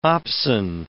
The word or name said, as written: opsin